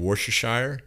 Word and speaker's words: Worcestershire: You could even like cover it in Worcestershire or mustard. You killed that. – I did. What, did I say it right or wrong? – I think you did.